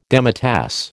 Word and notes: demitasse: American English

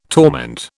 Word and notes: torment: British English

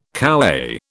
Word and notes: kowhai: British English

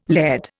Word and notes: laird: Indian

